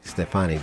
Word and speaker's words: Stefoni: Stefoni.